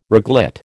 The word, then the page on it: reglet: American English